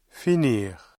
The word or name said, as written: finir